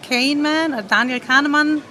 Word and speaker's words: Kahneman: Ein Buch, das mich tatsächlich sehr fasziniert hat, ist von, man kann den Namen sehr deutsch aussprechen, aber ich glaube, er wird englisch ausgesprochen, also Daniel Kahneman, Thinking Slow and Thinking Fast.